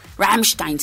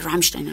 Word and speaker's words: Rammstein: Rammstein como decimos por acá en México Rammstein, se pronuncia Rammstein, amigos.